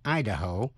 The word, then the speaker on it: Idaho: Tiểu bang Ohio, tiểu bang Ohio nhấn mạnh ở vần nhì, nhưng tiểu bang Idaho nhấn mạnh ở vần đầu. Xin lặp lại. Tiểu bang Ohio nhấn mạnh vần nhì, nhưng tiểu bang Idaho nhấn mạnh vần đầu.